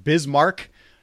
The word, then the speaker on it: Bismarck: also bitte, richtig aussprechen. Bismarck, North Dakota.